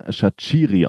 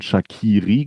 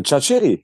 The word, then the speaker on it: Schachiri: Ich habe in der Rheinzeitung nämlich äh, vernommen, dass der Name Erion Schachiri ausgesprochen wird. Ähm, sowohl du als auch ich haben allerdings noch von Shakiri gesprochen. Das liegt nahe, weil es natürlich den ein oder anderen etwas bekannteren Fußballer gibt, der genauso geschrieben wird, der genauso ausgesprochen wird.